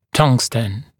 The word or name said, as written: tungsten